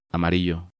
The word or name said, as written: Amarillo